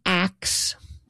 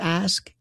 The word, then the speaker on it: ask: Well, the pronunciation ax instead of ask is not a, as I learned, it is not a modern mistake.